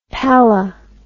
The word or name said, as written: pallah